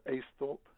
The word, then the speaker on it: Aisthorpe: Uh, is it Aisthorpe or Aisthorpe? – Uh, Aisthorpe.